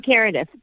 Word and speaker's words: Kareth: But people choose to put the accent in different parts of the name. And I'll make jokes. I'll say it's like, you know, carrot with a lisp, or it's like Karen and Meredith put together. And then inevitably, and these people will call me Kareef. And I'm like, I mean, Kareth.